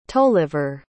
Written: tolliver

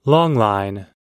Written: longline

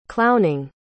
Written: clowning

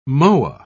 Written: moa

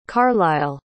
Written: carlisle

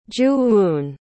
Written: joo-eun

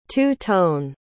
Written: two-tone

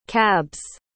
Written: cabs

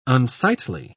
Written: unsightly

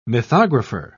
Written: mythographer

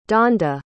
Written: donda

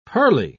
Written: pearly

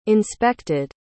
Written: inspected

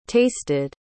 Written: tasted

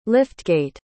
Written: liftgate

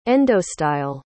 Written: endostyle

